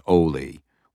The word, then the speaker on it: Oli: Wading through the brush with her was the boy she knew as Oli, which she pronounced with a long O rhyming with goalie.